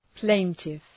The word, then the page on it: plaintiff: ‘pleıntıf